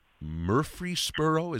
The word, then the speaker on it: Murfreesboro: Is it Murfreesboro? Is that how we pronounce that? – Yes.